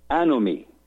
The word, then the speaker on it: anomie: in which he explored a concept that in English is spelled and pronounced anomie, A-N as in Nancy, O-M as in Mary, i.e. anomie.